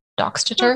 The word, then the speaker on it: Doxtator: D O X T A T O R. – Doxeter. Okay. Okay. That sounds right.